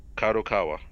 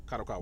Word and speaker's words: Kadokawa: Kadokawa. – Kadokawa.